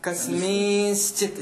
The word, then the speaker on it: कस्मिंश्चित्: वस्तुतः तत्र नकारः न उच्चारणीयः अस्ति कस्मिन् इति न कस्मि तत्र कस्मिंश्चित् इति अस्ति